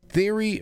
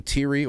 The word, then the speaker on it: Thierry: Theory Breton, that's how you say his name, Thierry or Theory Breton,